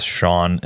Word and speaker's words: Sean: I am one of the very small percentage of people that understands how that is spelled, because my name is Sean and it uses the same Irish S-E-A.